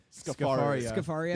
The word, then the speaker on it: Scafaria: This is the, how do you pronounce it? Lorraine Scafaria? – I don't know. – Lorraine Scafaria? – Scafaria.